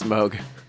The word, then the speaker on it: Moog: Mog. Where are you, Shaggy, to tell us? – Yeah. I learned from him. I think it's Moog. I don't know why. It's spelled like Moog, but it's Moog.